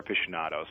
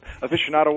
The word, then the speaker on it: aficionado: Aficionado. – Tell me, how am I even close on the pronunciation? – I say cigar aficionado. – So okay. You know what? – Aficionado, but aficionado works.